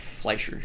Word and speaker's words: Fleischer: Fleischer.